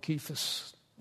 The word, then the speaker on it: Cephas: I'll call him Cephas instead of going back and forth, and you know who we're talking about. The C is a hard C, key like a K.